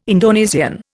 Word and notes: Indonesian: Indian

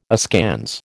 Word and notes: askance: American English

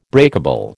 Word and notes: breakable: American English

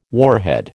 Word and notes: warhead: American English

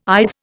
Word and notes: ides: Indian

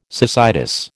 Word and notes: cecitis: American English

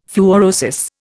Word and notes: fluorosis: Indian

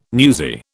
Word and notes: newsy: British English

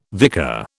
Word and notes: vicar: British English

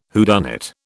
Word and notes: whodunit: British English

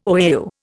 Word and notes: OAU: Indian

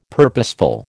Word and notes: purposeful: American English

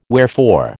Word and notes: wherefore: American English

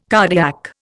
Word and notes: cardiac: Indian

